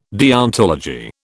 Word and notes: deontology: British English

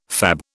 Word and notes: fab: British English

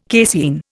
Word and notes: casein: Indian

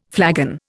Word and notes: flagon: Indian

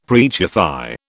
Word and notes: preachify: British English

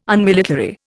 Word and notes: Unmilitary: Indian

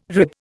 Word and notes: rib: Indian

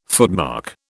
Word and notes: footmark: British English

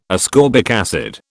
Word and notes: ascorbic acid: British English

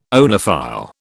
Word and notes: oenophile: British English

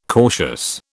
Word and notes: cautious: British English

